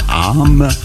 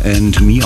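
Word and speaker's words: Âme: Am Anfang habe ich ihn Arm ausgesprochen. Tatsächlich wird aber And Me ausgesprochen und ich habe ihn sogar für eine Frau gehalten.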